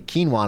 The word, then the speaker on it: quinoa: And finally, I'm like, oh, quinoa, which if you don't know, people, it's spelled Q-U-I-N-O-A. So it looks like quinoa. I don't know how I get quinoa because that's like K-E-E-N-W-A.